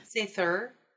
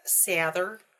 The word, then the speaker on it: Sather: Sather, which is the difference between a vocalized TH and like the silent TH, but it doesn't actually matter.